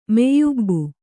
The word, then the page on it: ಮೈಯುಬ್ಬು: maiyubbu